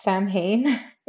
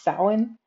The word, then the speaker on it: Samhain: It's not pronounced Samhain, it's Samhain.